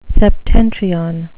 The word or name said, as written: septentrion